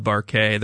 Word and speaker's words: Barkay: who is the co-managing partner of Barkay. It's spelled like bark, but it's called Barkay.